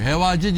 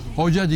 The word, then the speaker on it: Hoca: Hevace diye okunmaz, Hoca diye okunur.